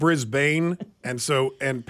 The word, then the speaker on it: Brisbane: Briz, so you could spell it B-R-E-Z. Brisbane. So that's a schwa sound. – Brisbane. – Stress on the first syllable.